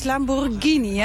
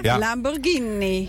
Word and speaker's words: Lamborghini: Want dat zegt Dirk tegen mij. Het is Lamborghini, hè? – Ja, Lamborghini.